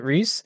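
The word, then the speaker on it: Rhys: Reese Darby? – Yeah. Is that how you pronounce it? Reese? – Yeah.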